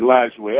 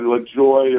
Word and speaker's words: Lajoie: The way you just said it, Lajoie. Other people call it LaJoy or yeah.